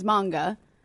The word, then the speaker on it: manga: the Japanese pronunciation is manga, but it's like saying, like, anime or anime.